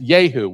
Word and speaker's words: Jehu: Now, Jehu in Hebrew would be pronounced Yehu.